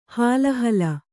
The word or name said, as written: ಹಾಲಹಲ